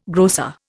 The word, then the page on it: grocer: Indian